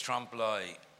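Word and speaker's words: trompe-l'œil: How do you pronounce it? – I think it's Trump Lie. – Is that how you... Who speaks French? – Fool the Eye, yeah. – Fool the Eye, Trump Lie.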